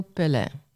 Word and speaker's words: Pelé: Maar het is dus officieel Pelé.